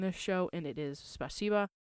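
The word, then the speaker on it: spasiba (спасибо): Now, I do want to say I know it ends in an O, but it is pronounced like an A, spasiba.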